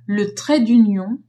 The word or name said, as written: trait d'union